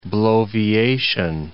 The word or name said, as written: bloviation